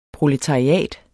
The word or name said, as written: proletariat